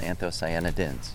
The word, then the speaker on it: anthocyanidins: Oh, I was saying that wrong. – Yeah, I think it is anthocyanidins.